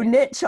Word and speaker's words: niche: so niche obviously rhymes with rich, so we have to call it riches and niches.